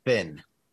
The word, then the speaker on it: Fionn: And it is spelled F-I-O-N-N. – Finn.